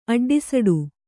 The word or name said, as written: ಅಡ್ಡಿಸಡು